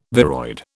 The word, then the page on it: viroid: British English